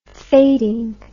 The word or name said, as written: fading